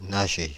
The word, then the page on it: nager: France (Paris); /na.ʒe/